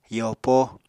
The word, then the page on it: yopo: apatani